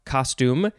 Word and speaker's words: costume: It looks like this one, you would emphasize the second syllable, so it would be costume. Costume, costume, costume. I guess you could also emphasize the first syllable if you want as well.